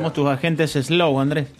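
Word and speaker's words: Slough: En inglés, el nombre de la localidad y centro de oficina, Slow, se pronuncia fonéticamente muy parecido a slow, a lento.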